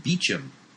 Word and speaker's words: Beauchamp: Like you know, the name Beauchamp, which is a common British name, even though it's a French word, it's pronounced Beecham.